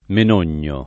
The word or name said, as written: Menogno